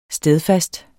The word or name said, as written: stedfast